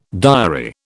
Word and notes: diary: British English

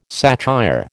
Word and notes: satire: American English

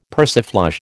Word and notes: persiflage: American English

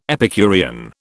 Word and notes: Epicurean: British English

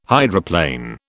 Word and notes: hydroplane: British English

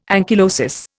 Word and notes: ankylosis: Indian

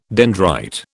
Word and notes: dendrite: British English

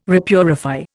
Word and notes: repurify: Indian

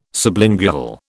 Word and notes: sublingual: British English

